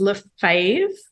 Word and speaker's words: Lefebvre: Yeah, it's Lefebvre, rhymes with behave.